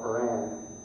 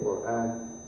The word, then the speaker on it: Quran: And we were also told that the Quran, the holy book, called Quran in the West, Quran, by most Muslims we say Quran, Arabic pronunciation, that book was the word of God and was pure and perfect.